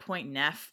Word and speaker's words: Portneuf: And I had to say Portneuf for the river just because I'm sure it's pronounced like Portneuf because, you know, how French gets butchered by the American tongue.